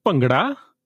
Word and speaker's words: Bhangra: So it's pronounced as Bhangra.